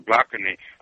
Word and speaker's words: Blakeney: That's B-L-A-K-E-N-E-Y, or Blakeney. I hope I'm pronouncing Joshua. – Yeah, Blakeney.